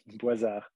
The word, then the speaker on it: Boisard: Boisard.